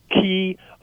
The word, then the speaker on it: chi: So in Greek they would call it chi, or if you're in a fraternity or something in a college, you know, it would be chi, right? That's how they pronounce it.